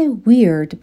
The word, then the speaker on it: weird: صفت weird به معنای عجیب غریب هست. به تلفظش دقت کنین. با اون چیزی که نوشته میشه فرق داره.